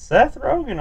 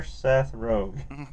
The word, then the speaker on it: Seth Rogen: No, it's Seth Rogen, all right. – Okay, yeah. – For sure, Seth Rogen. – I looked it up right now. Yeah, it's Rogen.